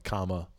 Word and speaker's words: Kamala: You have to emphasize the right syllables. – Yeah. – It's like Kamala.